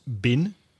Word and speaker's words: been: For example, in words like been, B-E-E-N. In connected speech, that's often pronounced bin.